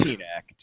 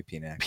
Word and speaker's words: Penac: Oh, am I saying that right? Is it Nocatus? – It's Penac. Just call me Penac. – Penac. I thought the P was silent.